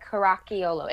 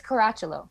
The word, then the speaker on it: Caracciolo: You guys got the ch sound because a lot of people say like Caracciolo. It's Caracciolo. – Caracciolo. It's almost like a silent I. – Yeah, it's a silent I. But you guys got I'm surprised you got the two C's correct because not everybody does. – Alessia Caracciolo. – Caracciolo. If you want to say it the real Italian way, it's Caracciolo.